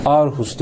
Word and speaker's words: Aarhus: Aarhus, Aarhus diye yazıyor, iki A ile. Danca'da bir harf var. A'nın üzerinde bir yuvarlak var. O diye okunuyor aslında. Aarhus.